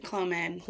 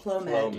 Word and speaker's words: Clomid: We started with, you know, the Clovid and, did I say that right? – Clomid. – Clomid. Mm-hmm. That's it, mm-hmm.